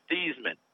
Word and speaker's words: Theismann: everyone will give you a blank look because the real family name is Theismann. Oh. They changed it to Theismann at Notre Dame to rhyme with Heisman, and they were trying to get the Heisman Trophy.